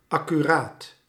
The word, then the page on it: soigneux: France; [swa.ɲø]